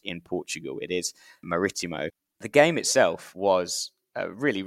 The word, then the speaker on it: Marítimo: And I keep switching between Marítimo and Marítimo because Marítimo is sort of the Brazilian pronunciation, which is the very limited knowledge of Portuguese I have is Brazilian Portuguese. But I kept getting corrected yesterday to say Marítimo.